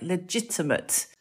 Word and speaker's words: legitimate: And why is one pronounced with a G, legal? Why is the other pronounced J, like legitimate? – That is really interesting.